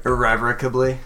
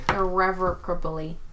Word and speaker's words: irrevocably: How do you say that word? – Irrevocably. – Irrevocably. I'm adding an R in there.